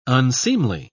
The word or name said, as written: unseemly